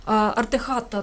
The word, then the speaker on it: Артехата: Це артехата, так? Скажи мені, будь ласка, чи я правильно наголос поставила? – Абсолютно вірно, інтуїція прекрасна щодо...